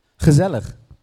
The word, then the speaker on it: gezellig: Gezellig. – Yeah, that's why I didn't try to pronounce it. Sounds like a sneeze. – Try it. – Hazarlig.